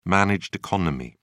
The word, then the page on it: managed economy: British English